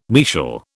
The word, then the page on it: mesial: British English